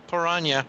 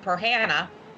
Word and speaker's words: piranha: Good thing there's no piranha. – Chuck Bronson. Oh, well, you'd hope so. – Or as somebody pronounced it to me, Perhanna.